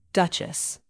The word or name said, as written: duchess